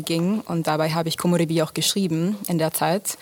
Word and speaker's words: Komorebi: Wie wird das ausgesprochen? Was für eine Sprache ist das? – Komorebi, das ist japanisch.